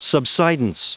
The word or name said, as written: subsidence